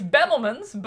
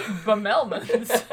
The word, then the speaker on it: Bemelmans: It's Bemelmans, but I like Bemelmans.